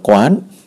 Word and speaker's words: Quan: Ở đó, hành nguyện của ngài Bồ Tát này là gì mà ngài lại có cái tên là Quan Thế Âm? Đó, cái câu vậy đó. Thì trước tiên về cái nghĩa của chữ Quan Thế Âm, quan hay còn đọc là quán, quan này là quan sát, hay là quán còn là nhìn, thế là thế gian, âm là âm thanh.